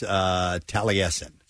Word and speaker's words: Taliesin: By the way, somebody's texting in the name of the house is pronounced Taliesin.